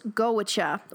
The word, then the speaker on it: Goitse: Cool thing about the name of the band, it is spelled G-O-I-T-S-E, but according to the Summerfolk website, it is pronounced Goicha, or something close to that.